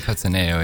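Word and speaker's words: Cattaneo: Van Peter Cataneo, zeg ik dat goed? – Peter Cataneo, ja.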